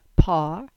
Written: Par

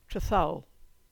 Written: Trethowel

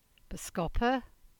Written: Boscoppa

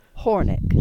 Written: Hornick

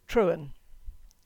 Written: Trewoon

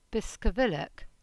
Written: Biscovillack

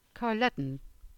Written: Carluddon

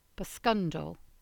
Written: Boscundle

